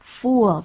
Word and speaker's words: fool: 对，而且像 Peter 刚讲的，注意那个母音，不要念作 u fool，就是个傻子，一个呆子。 Fool，而且它比较长，母音的部分比较长。